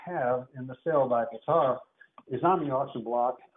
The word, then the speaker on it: Bitter: We have a piece, Carved Angel by Bittar, B-I-T-T-E-R. And it's not pronounced bitter. It's not pronounced bitter. It's Bittar, but it's with an E.